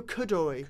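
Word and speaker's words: Coduri: Camille Kudori. – Kudori. – Not Midori.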